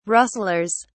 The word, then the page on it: rustlers: British English